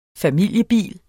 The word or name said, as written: familiebil